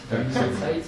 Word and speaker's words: Die Neue Zeit: Die Neue Zeit.